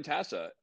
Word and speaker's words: Tasa: Pronounce it Jordan Tasa? Tasa? – Close enough. – how would you pronounce it? – It's Tasa. – Tasa. Okay. I'm here with Jordan Tasa, and Jordan was in Afghanistan.